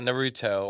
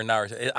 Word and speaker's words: Naruto: Yeah, Naruto or Naruto. Either or is good.